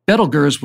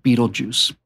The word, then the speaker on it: Betelgeuse: Betelgeuse was the correct pronunciation, but most people just called it Betelgeuse.